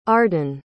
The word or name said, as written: arden